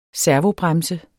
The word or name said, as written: servobremse